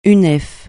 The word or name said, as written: Unef